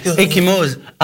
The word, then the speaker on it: ecchymose: Ecchymose. A-C-C-H-I-M-E-O-S.